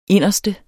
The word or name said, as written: inderste